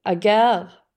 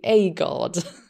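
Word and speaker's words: Ødegaard: Däremot så fattar jag först inte vem du pratade om när du med franskt uttal sa agar, uttalar du det som, va? Och vilket är rätt, antagligen. Men jag är så van vid det engelska uttalet, för här säger de A-guard.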